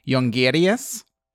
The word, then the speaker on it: Jongerius: He's Dutch, so I'll pronounce it in a Dutch way. His name is Nick Jongerius.